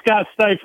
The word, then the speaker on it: Stuitt: And now we know how to say it. – Yep. Scott, Scott S-T-U-I-T-T.